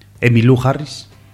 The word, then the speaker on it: Emmylou Harris: También vemos aquí una de las pocas mujeres que actúan en este documental, que es Emmylou Harris.